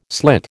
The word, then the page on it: slit: American English